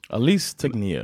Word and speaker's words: Alice Tegnér: Fast hon heter faktiskt inte Alice. Hon uttalas Alice. – Alice Tegnér. – Men det stavas likadant.